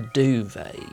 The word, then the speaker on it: duvet: A duvet, yes. And I think the correct pronunciation you'll find is duvet. There's a T on the end.